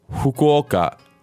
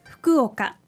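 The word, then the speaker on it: 福岡: So this name starts with a very interesting Japanese sound, fu, which is not exactly a fu, F-U, like it's spelled in English.Instead, it's sort of in between an F and an H. So it's a Fukuoka.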